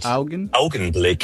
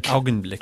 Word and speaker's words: Augenblick: Actually, am I saying that right? How do you say that? Is it Augen? – It's called Augenblick. – Augenblick. – Augenblick. – Yeah,